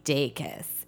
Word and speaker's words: Dacus: And Lucy Dacus is how I pronounce it. I believe it's actually Lucy Dacus, is a dope singer-songwriter.